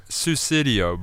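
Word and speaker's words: Sussudio: We're going to chuck on this Suswindo. Is that how you say it? – Susidio by Phil Collins.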